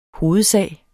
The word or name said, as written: hovedsag